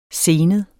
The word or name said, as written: senet